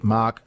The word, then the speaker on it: Marque: Adrian Flynn is the president of the Mark Sports Car Association of South Australia. G'day, Adrian. – How you doing? – Good, good. Now, it doesn't come across on radio, but Mark is spelled M-A-R-Q-U-E.